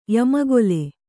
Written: ಯಮಗೊಲೆ

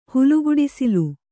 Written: ಹುಲುಗುಡಿಸಿಲು

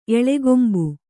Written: ಎಳಗೊಂಬು

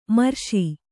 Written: ಮಿರಾಸಿ

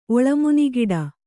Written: ಒಳಮುನಿಗಿಡ

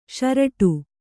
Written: ಷರೇಡು

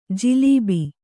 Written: ಜಿಲೀಬಿ

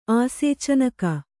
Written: ಆಸೇಚನಕ

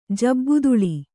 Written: ಜಬ್ಬುದುಳಿ

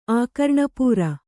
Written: ಆಕರ್ಣಪೂರ

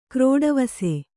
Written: ಕ್ರೋಡವಸೆ

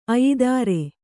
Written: ಅಯಿದಾರೆ

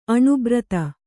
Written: ಅಣುಬ್ರತ

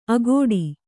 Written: ಅಗೋಡಿ

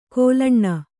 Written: ಕೋಲಣ್ಣ

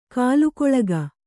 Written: ಕೂಲಿಕೊಳಗ

